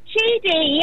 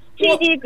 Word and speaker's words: Chidi: Chidi, yes, Chidi, you pronounced it very right, yes, I've got it right.